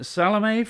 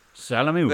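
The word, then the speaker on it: Salomé: Yeah, Salome from Chile. – Salome. Gonzalez. I knew you'd know that. – Salamu. – There's an accent on the E, isn't there? Graph, Salome.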